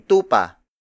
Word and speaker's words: tupa: For example, puting tupa, puting tupa, puting tupa. It means white sheep. Tupa, the Tagalog word for sheep, is spelled as T U P A.